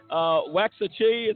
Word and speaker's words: Waxahachie: Waxahachie, it sounds like.